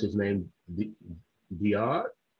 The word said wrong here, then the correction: Daoud: Is that how you pronounce it? – Oh, Daoud.